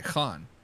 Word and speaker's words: Khan: Khan is spelled K-H-A-N. – Yeah, I was going to ask. Now, that begs the question. Is it Khan or is it Khan? – Khan. – Khan, okay. – Khan, yeah.